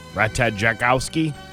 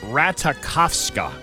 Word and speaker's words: Ratajkowski: we've been saying the name wrong. It's Ratakovska. See, you probably thought you were cool because you thought knew the J was silent, but uh, you know you ended it with an A instead of an I, didn't ah, you?